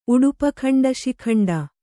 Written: ಉಡುಪಖಂಡಶಿಖಂಡ

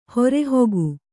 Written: ಹೊರೆಹೊಗು

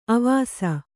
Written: ಅವಸೆ

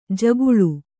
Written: ಜಗುಲಿ

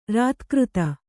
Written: ರತಿಕಾತಿ

